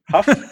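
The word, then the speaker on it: Hough: or I've got Brady Hoff. Is that how you say it? Huff. I've got Brady Huff on my bench.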